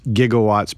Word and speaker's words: gigawatts: which can also actually be pronounced gigawatts, but a lot of physicists can go with the G on that one, or the soft G.